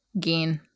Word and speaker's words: Gein: Gein? How do you say it? – Gein. – It's Gein.